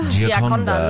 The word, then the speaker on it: Gioconda: I just want to double check I'm pronouncing that right. – Gioconda? – Gioconda. La Gioconda.